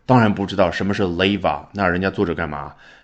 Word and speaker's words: 令和: 首先开头 leva，你已经听出来了，没有读成 rewa，因为啊，日语跟世界上很多的成熟语言主流的，比如说汉语和英语相比呢，缺失很多的音，辅音里面这个 r 它就没有，它只能读成了，那就是 leva。